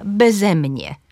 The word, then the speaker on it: beze mnie: Jeżeli przyimek jest dwusylabowy, to na drugą sylabę tego przyimka, beze mnie. Beze mnie.